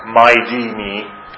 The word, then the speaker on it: MIDM: This is an approach that I developed called MIDM, which I pronounced My D Me, and which somebody else suggested sounds like Mighty Me, which I thought, gee, I should trademark that, but I forgot.